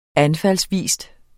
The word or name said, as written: anfaldsvis